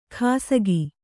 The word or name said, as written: ಕಾಸಗಿ